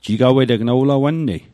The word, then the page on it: jigaweteg: Mi'gmaq